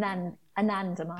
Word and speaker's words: anandamide: Was it andamide? Am I saying that right? – Anandamide. – Anandamide, okay, which is that bliss molecule.